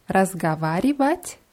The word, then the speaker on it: разговаривать: Ja, das ist ein langes Wort, deswegen sollte man darauf achten, auch alle Silben auszusprechen. Ich sage es nochmal langsam, also reden, sprechen heißt Vat. разговаривать.